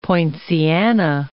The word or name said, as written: poinciana